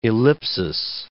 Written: ellipsis

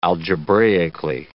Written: algebraically

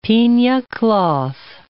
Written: pina cloth

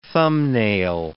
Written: thumbnail

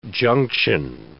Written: junction

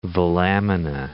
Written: velamina

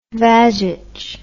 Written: vagitch

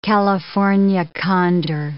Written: California condor

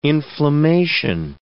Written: inflammation

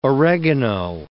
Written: oregano

